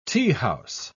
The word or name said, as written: teahouse